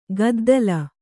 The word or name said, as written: ಕುದ್ದಲ